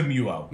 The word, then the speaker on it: Muo: You have, and I always say it wrong, is it the Muo? Is that what it is? The little wireless? – It is the Muo, Muo.